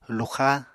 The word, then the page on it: luxá: Apatani